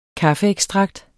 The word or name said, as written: kaffeekstrakt